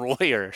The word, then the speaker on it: Royer: that uh, that Stan Rover was one. – Well, see, you're, you're cheating and you mispronounced his name because it's Stan Royer.